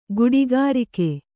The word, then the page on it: ಗುಡಿಗಾರಿಕೆ: guḍigārike